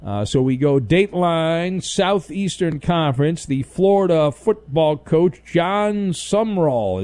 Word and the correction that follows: Sumrall: He became part of S-U-M-R-A-L-L. – I know, it's weird. It is Summerall. – Is it pronounced Summerall? – Yeah. – I feel like there's a letter missing. – It is. It's not spelled right, but that's how it's pronounced.